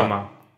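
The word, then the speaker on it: Коджіма (小島): Кодзіма чи Коджіма? – Коджіма? Коджіма. – Окей, Коджіма.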